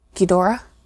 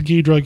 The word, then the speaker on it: Ghidorah: In Planet Eater, without spoiling anything major, this version of King Ghidorah, Ghidorah? – Sure. I've heard it actually pronounced a couple different ways. Ghidra, Ghidorah.